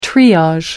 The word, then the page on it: triage: American English